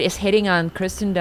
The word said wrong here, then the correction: Kirsten: Dunst. Kirsten Dunst. I always say it wrong.